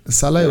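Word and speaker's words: Szoboszlai: Ich weiß übrigens immer noch nicht, wie der ausgesprochen wird, weil ja, Scholloy ist ja. Salai ausgeschrieben. – Salai ja, oder Salai oder äh, ja genau, Sal, er ist Salai, gut, auf jeden Fall der.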